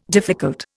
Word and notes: difficult: Indian

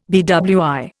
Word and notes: BWI: Indian

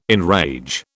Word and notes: enrage: British English

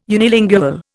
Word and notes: unilingual: Indian

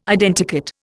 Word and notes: Identikit: Indian

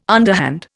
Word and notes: underhand: Indian

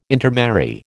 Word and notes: intermarry: American English

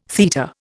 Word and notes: theta: Indian

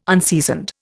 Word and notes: unseasoned: Indian

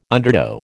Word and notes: undertow: American English

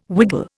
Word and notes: wiggle: Indian

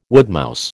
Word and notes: woodmouse: American English

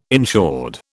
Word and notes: insured: British English

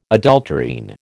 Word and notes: adulterine: American English

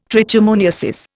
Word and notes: trichomoniasis: Indian

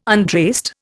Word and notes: untraced: Indian

